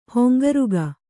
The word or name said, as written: ಹಂಗರಿಕೆ